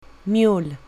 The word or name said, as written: mule